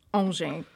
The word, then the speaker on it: Angers: So it's written Angers, but pronounced Angers.